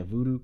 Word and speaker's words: Vudu: It's on Vudu. Am I saying that right? – Yeah, Vudu, Crackle.